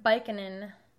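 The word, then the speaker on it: Byykkonen: Taylor Baikkanen.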